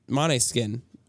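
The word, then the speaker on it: Måneskin: Maneskin. – Maneskin. Man, I've been saying that wrong my whole life.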